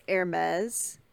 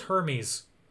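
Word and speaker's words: Hermès: They're like limited edition usually, and they're made by Hermes. Jeffree Star gives them away on his channel sometimes. – That's how it's pronounced? I thought it was Hermes.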